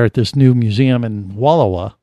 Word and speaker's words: Wallowa: Wallowa.